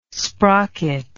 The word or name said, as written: sprocket